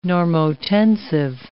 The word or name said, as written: normotensive